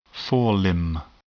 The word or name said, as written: forelimb